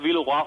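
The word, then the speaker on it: Villeroy: Also korrekt Wilroy und Boch, denn Wilroy ist ein französischer Name.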